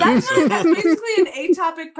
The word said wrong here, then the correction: ectopic: pregnancy. Ectopic. I don't know what the correct pronunciation is. – Ectopic. Ectopic. – Right. – Okay, because in EMT school, they told me atopic, but it's where it grows in the fallopian tube.